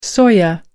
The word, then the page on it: soya: American English